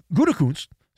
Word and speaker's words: Gutekunst: The GM in Wisconsin, Brian Gutekunst, make sure you say that carefully or you'll get in a whole lot of trouble. Uh, Brian Gutekunst, see, I said it properly.